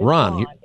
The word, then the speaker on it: Rhon: It's Ron. – I'm a girl. It's R-H-O-N. – Okay. R-H-O-N.